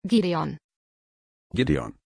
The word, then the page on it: gidéon: German